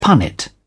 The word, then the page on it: punnet: British English